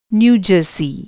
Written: न्यू जर्सी

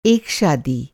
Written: शादी

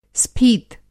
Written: स्फीत

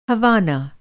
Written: हवाना

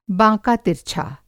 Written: बाँका तिरछा

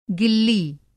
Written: गिल्ली

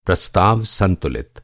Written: संतुलित

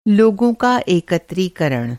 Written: एकत्रीकरण

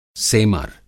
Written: सेमर

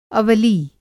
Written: अवरागार

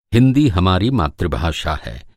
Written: मातृभाषा

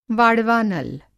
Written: वाड़वानल

